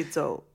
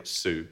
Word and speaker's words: Zhou: But it's actually Zhou. – Zhou. – Yeah. – But we say Su.